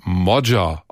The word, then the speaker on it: Magyar: Sein Name schreibt sich zwar Peter Magyar mit Y, der Nachname wird aber Modja ausgesprochen.